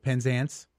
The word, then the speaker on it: Penzance: For this play, The Pirates of Penzance. Did I say it right? – Yes, you got it.